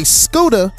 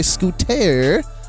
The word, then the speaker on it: Scooda: you say Scooda, but a lot of people spell it with a T, and it's like, oh, we don't say scooter. It's Scooda.